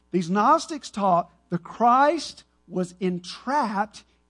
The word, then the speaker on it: Gnostics: They were called, remember, it begins with a G. The G is silent. They're Gnostics. And they taught the Christ, this is what they taught.